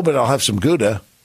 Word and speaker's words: Gouda: But they don't pronounce it Gouda. – Okay. – It's Gouda. – Okay. – With a little Gouda.